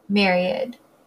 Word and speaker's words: myriad: It's spelled differently, but it is pronounced kind of like Mary plus id, myriad.